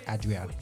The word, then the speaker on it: Adrianne: I've been told, I said Adriana, but like I've been told it's Adrianne, so you know, I have to pronounce my name properly.